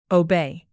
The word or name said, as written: obey